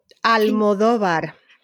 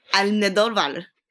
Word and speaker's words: Almodóvar: Almodóvar. – Almodóvar.